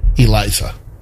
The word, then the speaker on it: Eliza: The way they have it written, it says pronounced E-8, or I'm sorry. IH-L-AY-Z-A. Well, dash A-H. – Eliza.